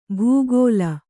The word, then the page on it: ಭೂಗೋಲ: Kannada